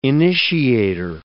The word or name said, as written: initiator